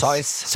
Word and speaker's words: Seuss: Es ist ein Animationsfilm aus Amerika vom Dr. Spricht man ihn aus Süß oder Seuss? – Seuss. – Seuss. Dr. Seuss ist die Geschichte.